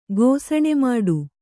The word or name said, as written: ಗೋಸಣೆಮಾಡು